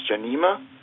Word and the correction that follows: Jamnia: Jamnia, yes.